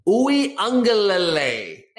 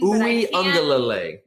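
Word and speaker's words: Uiagalelei: I can hear it in my brain, Uwe Ungalale. Make myself pronounce it. – It's correctly. That ung. You don't see a G, but there's an ung, like to a tongue of Iloa.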